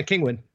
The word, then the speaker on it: Kinguin: We use Brad's technique of getting a, was it Kinguin? I don't pronounce it. Like Penguin? Kinguin license? Mm-hmm. And then the... – Yeah, Kinguin.